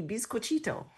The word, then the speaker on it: Bizcochito: Mexico one, I'm going to butcher the name, is something like a Bisco Quito or something. – That's great. The official the official cookie of New Mexico is indeed the Bizcochito. – Bizcochito.